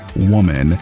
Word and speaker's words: WOMXN: But anyway, after the renaming of the center, they realized that it may not be as inclusive as they originally hoped, because WOMXN is still pronounced woman, and well, blind people will have no way knowing that the new woke spelling is the one that's being used.